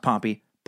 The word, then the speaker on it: Pompey: Also, if you ever talk about ancient Rome again, please try to remember that General Pompey is pronounced Pompey. Pompeii is a city that was destroyed by a volcano in ancient times. Fucking idiot."